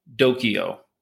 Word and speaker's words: Dokyo: The one in Corpus Christi was called Dokyo. D-O-K-Y-O. So spelled like Tokyo, replace the T, put in a D.